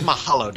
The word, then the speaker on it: Mahalo: He's like Sam Malone. Sam Malone. Yeah. No, that's Mahalo, Dad.